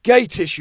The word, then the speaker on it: Gatiss: And then Russell kept going, oh, it's Mark Gattis, can't believe it's Mark Gattis. And then Mark Gattis finished the conversation going, it's pronounced Mark Gatiss, you like in a thespian.